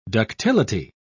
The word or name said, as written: ductility